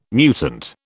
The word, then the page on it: mutant: British English